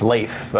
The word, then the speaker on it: Leif: I believe it's Leif. It rhymes with rape, if you remember. – It doesn't really rhyme, but he's been murdered.